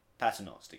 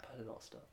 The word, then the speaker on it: Paternoster: The Paternoster de Gang? – Is that how you say it? – Paternoster Gang, yeah. – Paternoster, okay.